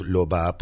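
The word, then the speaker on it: Lauba: La Uba se escribe, pero es Loba porque nació en Túnez y desarrolla hasta el día de hoy su carrera absolutamente en el continente, en Francia.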